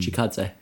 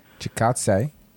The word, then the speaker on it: Chikadze: Chikadze. Chikadze.